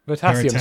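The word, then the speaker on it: Veritasium: And then a final topic for the dystopian tech is from a channel called Virtuism. – Virtuism? – Yes. I might butcher the name, to be honest. Virtasium, sorry. I keep saying virtuous. It's Virtasium.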